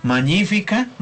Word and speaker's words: Magnifica: Unos la pronuncian en latín de la iglesia. Magnífica. La G y la N como ñ.